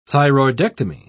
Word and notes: thyroidectomy: British English

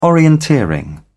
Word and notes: orienteering: British English

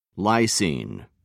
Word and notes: lysine: American English

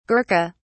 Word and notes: gurkha: British English

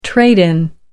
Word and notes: trade in: American English

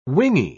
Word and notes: wingy: British English

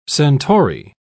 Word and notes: centaury: British English

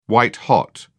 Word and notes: white-hot: British English

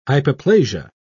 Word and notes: hypoplasia: British English

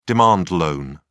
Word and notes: demand loan: British English

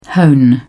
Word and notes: hone: British English

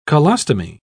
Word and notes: colostomy: British English

